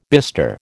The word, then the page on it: bister: American English